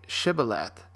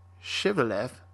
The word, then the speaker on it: Shibboleth: If he replied no, then they said, "Say Shibboleth," and if he said Shiveleth, because he could not pronounce the word correctly, they seized him and killed him at the fords of the Jordan.